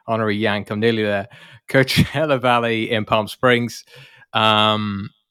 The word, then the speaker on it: Coachella: but I presume it's pronounced Coachella. – That's usually how people say it. – Yeah, Coachella. Yeah.